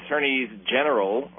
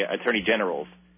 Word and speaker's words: attorneys general: I like saying it that way, which is the proper way to say it. Attorneys general of most of the United States of America. I heard somebody today say attorney generals, and they're not generals.